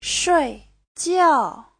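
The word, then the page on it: shuijiao: shui4jiao4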